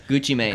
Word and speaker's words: Gucci Mane: Gucci Mane, is that how you say his name? – Yes, Gucci Mane.